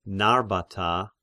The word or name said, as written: Narbata